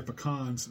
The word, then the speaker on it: pecans: And as my grandmother used to say, they're pecans, not pecans. Pecans go under the bed.